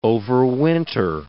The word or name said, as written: overwinter